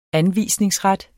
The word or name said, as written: anvisningsret